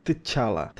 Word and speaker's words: T'Challa: É, tem um T, um T mudo meio estranho. É T'Challa.